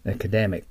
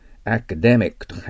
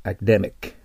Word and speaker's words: academic: Academic となります。はい、発音記号を見てください。ね。アカデミックと書いてありますね。アカデ、デのとこが強いですね。アカデミック。はい。 The professor asked me for an academic essay. アカデミックはアクセントに気をつけないといけません。アカデミックじゃありません。